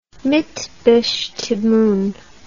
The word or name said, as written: mitbestimmung